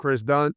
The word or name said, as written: Kris Dunn